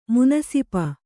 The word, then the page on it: ಮುನಸಿಪ: Kannada